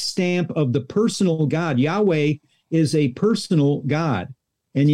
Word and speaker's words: Yahweh: But that name is thousands of times mentioned in the Old Testament, YHWH, and we believe the pronunciation is Yahweh.